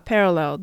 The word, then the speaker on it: parallel: 発音が難しい単語は parallel だと思います R と L の音をしっかり区別して発音しましょう